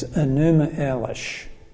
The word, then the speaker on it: Enuma Elish: When it comes to creation, the most best known is Enuma Elish. I'll spell that for you. E-N-U-M-A, Enuma, and then Elish. E-L-I-S-H.